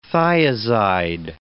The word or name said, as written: thiazide